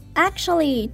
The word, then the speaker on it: actually: 最后一个发音要注意的部分是 actually， actually 这个字的 T-U-A 要发有点像 C-H 的音，然后重音在第一音节，所以会变成 Actually, actually.